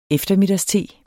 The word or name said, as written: eftermiddagste